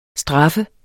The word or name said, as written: STRAFFES